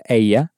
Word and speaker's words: ella: Paella is spelled P-A and then E-L-L-A. And of course, because you know how to pronounce paella now, you can pronounce the word for she with that double L sounding like a Y inside. So, keeping in mind it's spelt E-L-L-A, how would you say she has in Spanish? – Ella ha.